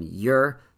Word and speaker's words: Ure: I'm told on good authority that's how you pronounce this guy's name, spelled U-R-E, and told to me by one of his teammates. It's like Your, Y-O-U-R, or something like that.